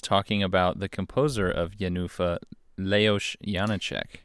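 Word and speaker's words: Jenůfa: We're really on the fence as to how this is actually pronounced. – I think it's Jenufa. – So when we... – In Czech. – When we saw it, that was the way that people sang it. However, I know in the Czech language that usually the first syllable is the one that's emphasized. However, it is someone's proper Christian name, so those rules don't actually matter.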